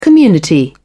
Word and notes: community: British English

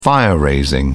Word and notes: fire-raising: British English

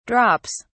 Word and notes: drops: British English

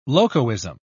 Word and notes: locoism: British English

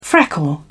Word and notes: freckle: British English

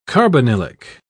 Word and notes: carbonylic: British English

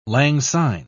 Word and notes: langsyne: British English

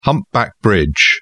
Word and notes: humpback bridge: British English

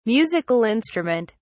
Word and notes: musical instrument: British English

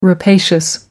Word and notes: rapacious: American English

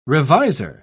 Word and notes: reviser: British English

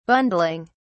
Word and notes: bundling: British English

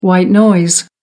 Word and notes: white noise: American English

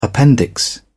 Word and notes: appendix: British English

